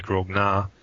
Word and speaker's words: grognards: the grognards would play, or as the French pronounce it, grogna,